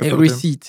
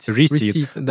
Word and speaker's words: receipt: Receipt. Receipt. Он пишется с буквой P, очень похож на слово рецепт.